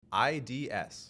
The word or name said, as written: IDS